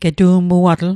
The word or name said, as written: getu'muatl